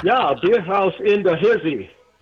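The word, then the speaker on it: Bierhaus: H-A-U-S. – Beer house. – It's just a house, man.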